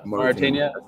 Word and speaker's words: Mauritania: Burkina Faso versus help me with the pronunciation because I always get Mauritania, I think, in English.